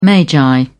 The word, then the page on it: magi: British English